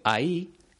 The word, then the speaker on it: ahí: La única diferencia es que la primera lleva tilde en la i y se dice ahí.